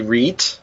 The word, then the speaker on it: Rhett: It's actually Reet. In German.